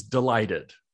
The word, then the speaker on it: delighted: And I have to say it that way because the way we say that word in English is delighted. And the first syllable is a schwa. It doesn't have a shape. It's not a pure vowel.